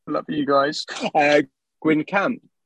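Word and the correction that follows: Guingamp: if that's how you say it, with their Umbro set shirts. – I think it's pronounced Gangon, and it's one of my favourites of the year so far.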